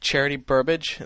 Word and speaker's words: Charity Burbage: He's scared at the sight of Charity Burbage. Is that how you pronounce the name? – Yep. Her name.